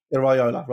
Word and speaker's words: Iraola: How do you pronounce his name correctly? – Andoni Iraola. – Iraola, right, yeah, I thought it was that.